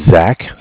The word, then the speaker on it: Zach: My name is Zach, spelled Z-A-C-H. – Mm-hmm. – I'm calling from Bank of Cardiff. Cardiff is spelled C-A-R-D-I-F-F.